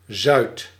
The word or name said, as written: southern